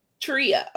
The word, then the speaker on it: Treya: Treya. Okay, I got you now. So welcome, Miss Coach Treya.